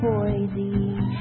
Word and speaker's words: Boise: Lots to see in Boise. But there is no Z in Boise. So if you're from out of town, please get this one thing down. It's really not hard to pronounce.